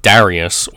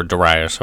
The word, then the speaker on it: Darius: Of course, eventually the kingdom of Darius, or Darius, however you would like to pronounce it, uh, would collapse under the march east of Alexander the Great.